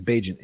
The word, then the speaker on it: Bagent: I think it's Bagent. Agent. It rhymes with agent.